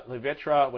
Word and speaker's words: Levitra: Because I don't think they have generic Levitra or Levitra and Cialis. I don't think they have generic versions of those. I don't know if it's covered by patents or not. – Levitra, which I keep on saying it wrong, I'm sorry.